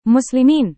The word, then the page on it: muslimin: Indonesian